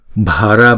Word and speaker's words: Bhara: Om Bhara Bhara. Chữ B và chữ H chúng ta ghép lại chúng ta đọc là bờ.